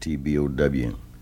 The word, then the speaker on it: T-Bow: T-Bo. It's a French name. I spell it T-B-O-W. Well, that was the wrong way to spell it. So everybody was calling me T-Bone, so I let them call me T-Bone, but it's T-Bow.